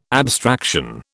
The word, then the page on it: abstraction: British English